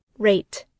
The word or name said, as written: rate